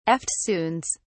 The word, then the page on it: eftsoons: British English